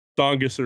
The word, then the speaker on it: Tsongas: Did I say that right? – Is the Tsongas? Tsongas Arena. Yeah, you got it.